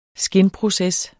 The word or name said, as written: skinproces